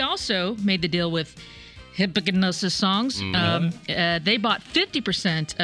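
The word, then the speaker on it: Hipgnosis: I think it's pronounced hypnosis, even though it's spelled weird.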